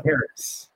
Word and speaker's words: Charis: Charisse? – Yeah, it's Charis. Yeah. – Charis. Yeah. Like the spiritual gift Charis.